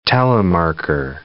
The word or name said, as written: telemarker